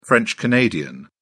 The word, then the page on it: french canadian: British English